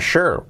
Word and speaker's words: sure: I'm not sure what to do. I'm not sure. No estoy. I'm not sure. Seguro. Sure. Pronunciación sure. con ese -sh, con SSH. I'm not sure. Aunque no haya SH, ese sonido.